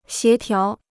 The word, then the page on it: 协调: xié tiáo